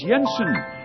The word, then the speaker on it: Jensen: Jens Jensen. Spelled with a J, pronounced like a Y.